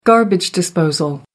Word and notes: garbage disposal: American English

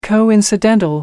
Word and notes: coincidental: American English

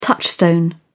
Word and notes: touchstone: British English

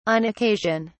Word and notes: on occasion: British English

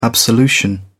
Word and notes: absolution: British English

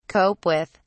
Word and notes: cope with: British English

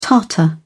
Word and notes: tartar: British English

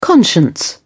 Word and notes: conscience: British English

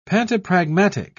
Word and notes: pantopragmatic: British English

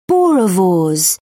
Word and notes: boerewors: British English

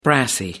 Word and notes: brassy: American English

